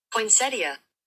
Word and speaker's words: poinsettia: Okay, I have the pronunciation. Let's see if we can hear this. – Okay. – Poinsettia. – Oh, you're right. It is poinsettia.